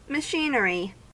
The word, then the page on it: machinery: American English